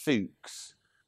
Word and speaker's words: Fuchs: Apparently it's pronounced Fuchs, but it's spelt Fuchs. Which I quite liked.